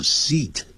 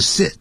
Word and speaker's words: seat: 注意到 seat, S-E-A-T, 念长音 seat, 长元音，不要念成 sit, 如果是 sit 的话是不及物动词。